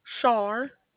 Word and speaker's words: Char: Char. – Can you spell that for me? – C-H-A-R. – C-H-A-R.